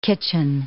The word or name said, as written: kitchen